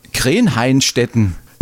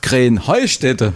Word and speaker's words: Krähenheustätte: Ja, ihr saget immer Krenheinstetten. Und dabei heißt es doch Krähenheustätte, oder wie ist es? Krähenheustätte. – Ja, genau, und das ist die Betonung auf dem Heu.